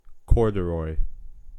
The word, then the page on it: corduroy: American English